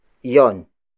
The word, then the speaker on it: 'yon: For the sake of convenience, Tagalog speakers tend to shorten iyon into yon. Yon. Yon, which is spelled as apostrophe Y-O-N, which we covered in lessons 3 and 4.